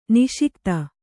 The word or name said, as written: ನಸಿಗುತ್ತ